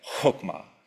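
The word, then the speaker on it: chokmah (חכמה): You can kind of say it like chokmah.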